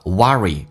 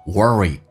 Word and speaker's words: worry: You worry 美式发音发 R 重度卷舌，英式发音叫 worry，美式发音叫 worry，你在担忧。